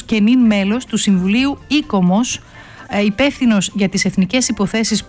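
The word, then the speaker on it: ICOMOS: Αν δεν το γνωρίζετε, αυτό ακριβώς κάνει το ΙΚΟΜΟΣ ή το, δεν ξέρω αν πρέπει να το πω, ΑΙΚΟΜΟΣ, κύριε Τανούλα. Πρώτα απ' όλα καλησπέρα σας. – Καλησπέρα σας. Ένα ΙΚΟΜΟΣ θα το λέγαμε, γιατί έτσι προφέρουν όλο με τον γαλλικό τρόπο.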